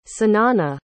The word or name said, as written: sanannah